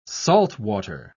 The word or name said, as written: saltwater